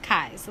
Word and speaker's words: Beauregard-Keyes: It's spelt like Keys, but it's pronounced Keyes.